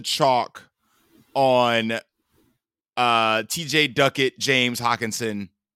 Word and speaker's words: Hockenson: And as I mentioned, you know, I'm gonna try and say this man's name as many different ways as possible, but I'll start with the correct way to say it. Thomas James Hockenson is in a revenge game, and people are gonna be looking to naturally run back this game with the Minnesota side.